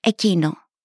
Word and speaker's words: εκείνο: Looking at the word for this warrants a look at the word for that, which is εκείνο.